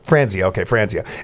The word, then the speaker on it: Franzia: How do you pronounce it? – Franzia.